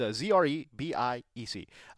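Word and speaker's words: Zrebiec: The Baltimore Suns, Jeff, I never know how to say his name. Zrebeck? – Yeah, that sounds good. – All right. Jeff Zrebeck. Follow him on Twitter. It's Zrebeck.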